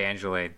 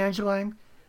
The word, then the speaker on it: Angeline: That's the way that the coaching pronounced the name. So I think that's right. I, I always said Angeline, but they say Angeline, so I'm going to go that way with it from now on.